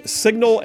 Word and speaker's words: Cygnal: John Rogers, Signal Analytics. That's spelled C-Y-G-N-A-L, Signal Analytics.